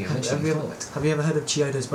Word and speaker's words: Chiodos: It's by Chidos. – Choidos. – I said Chiodos. – I don't know how to say it, okay? – It's Chiodos.